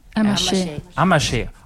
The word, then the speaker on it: Amacher: Marianne Amacher? – Amacher. – Oh, now I see.